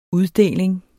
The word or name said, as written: UDDELING